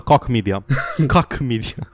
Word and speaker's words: Koch: Coach Media, uh, K-O-C-H. – Coke. E Coke?